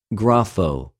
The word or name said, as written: γραφω